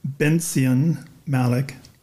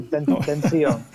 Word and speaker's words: Bencion Malik: Well, how did Zadie, whose full name was, let's see if I can say this right, Bencion Malik, did I do okay? – Correct. – Okay. – You did fine. Bencion.